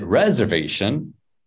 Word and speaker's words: reservation: And here, something to pay attention to is where the stress is. Is it reservation, or reservation? – Reservation. – Bum, bum, bum, bum. Reservation. Okay, so it goes.